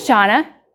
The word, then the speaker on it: Shawna: But it rhymes with Awana. And so on a regular basis, anytime I call, so I add like a dramatic pause. So I call, I'm like, hi, this is Shawna with Awana.